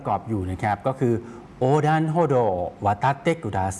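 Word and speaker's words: โ อดันโฮ โด (横断歩道): โอดันโฮโด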